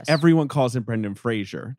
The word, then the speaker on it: Fraser: We're saying it like Fraser Crane, but he's Fraser.